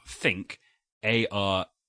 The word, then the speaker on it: Thinkarete: but it's spelt Think, A-R-E-T-E. – So let's settle right now, because this is an audio medium, unfortunately, and we have to call this something. This is Thinkarete. – I think it's Thinkarete.